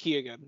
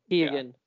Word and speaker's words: Keoghan: I'm head over heels in love with Barry Keoghan. Is that how I say it right? – Just Keoghan. It's not Keoghan. Yeah.